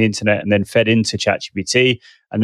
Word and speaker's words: ChatGPT: But yes, I want to run off now and go and have a look at ChatGTP and see. Did I just say it wrong again? I did. – Just to clarify as well, for those people interested, the GPT part of ChatGPT stands for generative.